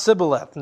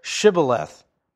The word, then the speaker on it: Shibboleth: We can see them pronounced Sibboleth instead of Shibboleth.